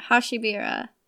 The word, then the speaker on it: Hashibira: So Hashibira, am I pronouncing that right? – I think so. Yeah. – Hashibira.